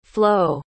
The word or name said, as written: flo